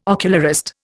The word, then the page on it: ocularist: Indian